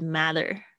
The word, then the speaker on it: matter: 嗯，这个 the matter 有没有发现它是 m a t t e r？它的美式发音很多也是是 matter，当然你也可以念成 matter。